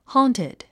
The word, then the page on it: haunted: hɔ́ːntid; ホーンティド